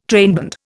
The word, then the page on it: trainband: Indian